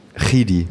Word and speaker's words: Chidi: Kidi wird es ja ausgesprochen. – Wie? – Chidi. – Chidi, genau. – Okay. Chidi. Da Georgia. – Ah ja, okay, geil. Genau. Chidi wird es ausgesprochen und ja, das ist einfach, also so ganz realisieren kann ich das auch noch nicht.